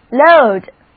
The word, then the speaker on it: load: load 本身也是一个动词，意思是装载、负担。注意字母组合 O-A 的读音是 O。